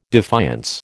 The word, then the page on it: defiance: American English